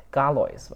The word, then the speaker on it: Galois: 上期节目里何峰提到了一个数学家的故事，是那个发明群论的伽罗瓦， Galois 吧，就是那应该那个 S 不发音，是一个法文的名字。